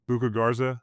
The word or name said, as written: Luka Garza